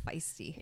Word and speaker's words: Feist: Feist is how I've always pronounced it and heard it pronounced, but I could be wrong.